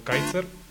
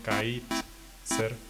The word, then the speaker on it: Kaithzer: Me encuentran como Kaiser. Se escribe con una TH en medio, Kaiser.